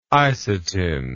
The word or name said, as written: isatin